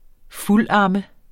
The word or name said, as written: fuldamme